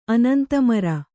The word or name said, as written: ಅನಂತಮರ